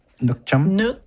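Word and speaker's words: nước chấm: Okay, so actually, you mentioned, how do you pronounce it? Nuoc cham? Okay. – Yeah, there you go.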